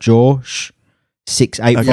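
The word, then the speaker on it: Jawsh 685: The guy that made it and made the beat, his name's Josh, but spelt J-A-W-S-H. So it's like Josh 685.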